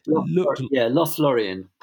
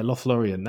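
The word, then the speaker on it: Lothlórien: and I don't know if I pronounce this correctly, but Lothlorien, is it? – Lothlorien. – Yeah, Lothlorien.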